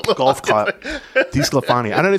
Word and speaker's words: DiSclafani: So the Twins' investment in DiSclafani, I said it right that time.